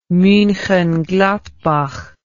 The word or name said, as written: Munchengladbach